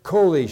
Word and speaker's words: choli: C-H-O-L-I, that's how the English pronounces it. Coli. Surely he took up our sicknesses, literally, and carried our makob, M-A-K-O-B, pain.